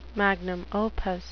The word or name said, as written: magnum opus